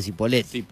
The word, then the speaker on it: Cipolletti: Pero la gente de Cipolletti le dice Cipolletti. – Yo hablo como me parece a mí. – Listo. – Pero se llama Cipolletti.